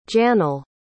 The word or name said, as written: Janel